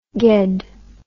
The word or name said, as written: gedd